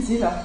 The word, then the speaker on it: Sylow: Und die meinten, Sylow ist zwar kein norwegischer Name, sondern kommt woanders her, Deutschland, Polen, aber trotzdem sind sie der Auffassung, man sollte es Sylow aussprechen. Und ich werde mir jetzt mit viel Mühe nach mehr als 20 Jahren angewöhnen, Sylow zu sagen.